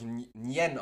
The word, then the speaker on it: Nguyen: Aber wenn ich jetzt ähm, äh, Li Fong Nguyen bin, und, oder das wird ja, glaube ich, Nien ausgesprochen, äh, wenn ich Li Fo Nien bin und mache mir China Imbiss Saigon Dragon auf.